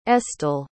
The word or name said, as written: estill